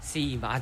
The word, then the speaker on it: Simba: Simba, entonces sí lo dije bien al comienzo, Simba.